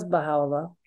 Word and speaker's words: Baha'u'llah: Who was Baha'u'llah? – Yeah, it's a great question because you pronounced his name, you know, very well.